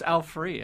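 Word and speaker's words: Frueh: But I've been pronouncing it Frew until just recently. – Well, it's F-R-U-E-H. – I know. But it's Al Free, actually.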